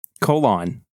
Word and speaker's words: Colan: Creators Dan Adkins was an inker, Gene Colan. Colon? I don't know. Was the artist? – Gene Colon. – Colon. Sure. The French pronunciation.